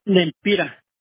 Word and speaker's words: Lempira: ¿Vampira? Gracias, Vampira. – Lempira.